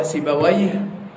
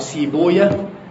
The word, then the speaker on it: سيبويه: ولذلك في قصة الكسائي وسيبويه، سيبويه أو سيبويا، النطق كده والنطق كده ماشي، نعرف يعني ممكن تتلاقي حد يقول سيبويا، فأنت ما تقولش إن هو غلط فيه. المحدثين يقولوا إيه؟ سيبويا. النحاة يقولوا سيبويه.